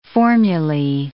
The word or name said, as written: formulae